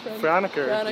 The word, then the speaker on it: Franeker: How do you say it, like Franeker? – Franeker. Yeah, Franeker. I was born around there.